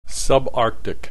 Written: subarctic

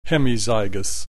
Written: hemizygous

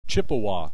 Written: Chippewa

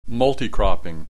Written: multi cropping

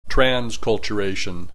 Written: transculturation

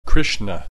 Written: Krishna

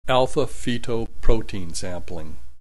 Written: alpha feto protein sampling

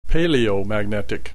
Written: paleomagnetic

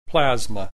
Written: plasma

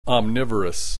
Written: omnivorous

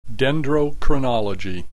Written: dendrochronology

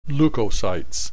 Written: leukocytes

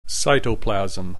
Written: cytoplasm

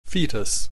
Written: fetus

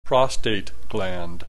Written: prostate gland

